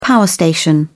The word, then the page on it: power station: British English